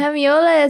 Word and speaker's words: hemiola: It's spelled here H-E-M-I-O-L-A. – I don't know what that is, but I'm going to say correct. – I say it's correct. – It's correct. – Yes. It's hemiola. Yeah. Hemiola is a rhythm pattern denoting three beats in the space of time occupied by two beats.